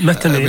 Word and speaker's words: Metheny: Pat Metheny. Och, eller är det sån en uttale? – Metheny, vill jag sagt.